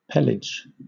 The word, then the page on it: pelage: British English